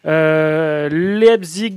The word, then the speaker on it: Leipzig: Leipzig, Lyon, eh bien.